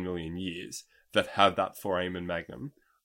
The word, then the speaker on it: foramen magnum: The strongest indicator of whether these species were bipedal or not is the placement of the, and Dave, correct me if I'm wrong in the pronunciation here, the foramen magnum. – Foramen magnum.